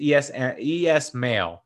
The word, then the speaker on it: Esmail: I can't pronounce his name right. It's Esmail, so Esmail.